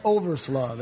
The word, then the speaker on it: Overslaugh: How do you say it? Overslaw? Overslaw. That's O V E R S L A U G H.